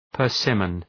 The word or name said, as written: persimmon